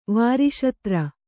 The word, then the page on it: ವಾರಿಶತ್ರ: vāri śatra